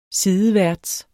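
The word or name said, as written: sideværts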